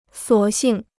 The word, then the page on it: 索性: suǒ xìng